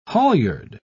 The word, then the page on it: halyard: British English